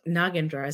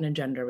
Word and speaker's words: Nagendra: And they also, like Nagendra is how you pronounce our name, but they thought Nagendra was easier to say because that's how someone said it. And so now over time, my brother says Nagendra, I say Nagendra, I say both.